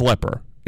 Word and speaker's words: Lepper: I'm thinking it's Lepper because it's Dr. Brad L-E-P-P-E-R. Is that Lepper or Leaper? Wouldn't Leaper be L-E-E-P-E-R or L-E-A-P-E-R? I think that would be Leaper, not Lepper. But that's Lepper because the name of the gentleman, Dr. Brad Lepper, is L-E-P-P-E-R.